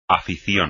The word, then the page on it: afición: espagnol